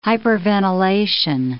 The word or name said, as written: hyperventilation